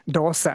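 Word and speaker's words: dosa: I was making sure I hadn't misled you the other day by saying Dorsha is pronounced Dorsha, not Dorsa. I asked my parents, and they said, yes, we say Dorsha, but some people down south in Kerala say Dorsa. And apparently, if you're Tamil-influenced in Kerala, you say the Sa, and everyone else says Sha.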